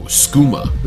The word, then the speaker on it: Skooma: S-K-O-O-M-O. Skooma. – Isn't that skoomo? – No. – That's an A at the end? – Yeah. – Oh. – Skooma. S-K-O-O-M-A.